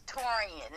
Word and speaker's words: Taurean: Yes, Taurean. It's spelled T-A-U-R-E-A-N.